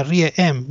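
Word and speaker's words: R.E.M.: El grupo, yo le digo REM, pero se pronuncia REM, ¿no? – Sí, es medio raro.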